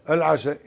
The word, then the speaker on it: العشاء: العشاء. الكتابة واحد لكن النطق إيه؟ والمعنى متباين. فالعشاء اللي هي الصلاة بكسر العين، والعشاء اللي هو الأكل بفتح العين.